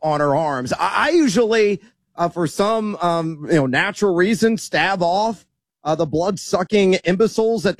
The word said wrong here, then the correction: stave: Isn't it stave? S-T-A-V-E? Is it pronounced stav? – Oh, did I say stav? I meant stave.